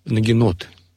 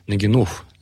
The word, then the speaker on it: нагинот: И вот еще одним сложным термином является термин «нагинот», или иначе его произносят как «нагинов», «нагинот».